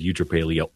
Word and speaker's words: eutrapelia: It's like a virtue called like eutrapelia.